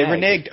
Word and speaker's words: reneged: Hold on, I'll look up the pronunciation on Webster. – Yeah, we need to stop pronouncing it. Surely the guy would find the pronunciation of this. – Reneg. – I got it, hold on. – Yeah, it says reneg.